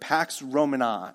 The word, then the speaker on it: Pax Romana: And there's a name for this given, and I can never quite say it right, but Pax Romana is the way that you say it, or Peace of Rome, or Roman Peace.